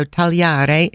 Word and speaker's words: tagliare: The verb, and this is interesting for you, for anyone, the verb tagliare, and keep in mind when there's a G in the middle of a word in Italian, the G is not pronounced. So tagliare is spelled T-A-G-L-I-A-R-E.